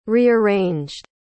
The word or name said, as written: rearranged